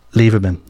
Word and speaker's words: Lieberman: Robert Flack released it in 1972, and then we had Laura Liberium. I can't even pronounce that. Do you know how to pronounce that? Hang on, I'm going to ask someone off air here. Do you know how to pronounce that? – Lieberman. – There you go. Laura Lieberman, yeah? There you go. I said it right that time.